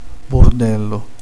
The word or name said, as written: casino